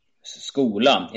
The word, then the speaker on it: skola: It seems to me Swedish pronounces the E separately at the end, Schula. – Skola.